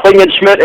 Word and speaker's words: Klingensmith: Did I say your last name right, correct, sir? – Yes, sir. Klingensmith, and God bless you, sir, and God bless your listeners in Jesus' name.